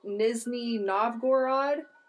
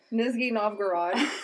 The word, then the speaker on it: Nizhny Novgorod: He was from Nizhny Novgorod. – Exactly how you say it. – Nizhny Novgorod, the Soviet Union.